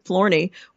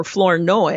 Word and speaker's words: Flournoy: Florney, or Flournoy, I think is probably the correct way to pronounce it,